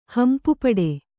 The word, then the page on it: ಹಂಪುಪಡೆ: Kannada